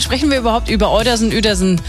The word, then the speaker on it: Uetersen: Es gibt einen Ort in Schleswig-Holstein, der spricht sich vorne mit Ü, schreibt sich aber nicht mit Ü. – Na, welcher kann das sein? – Uetersen. Ja. Eigentlich Uetersen, wenn man es mal so liest.